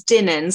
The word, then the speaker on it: Doineann: I think it's Dinan. So it's spelled D-O-I-N-E-A-N-N. So apologies if I mispronounced that now.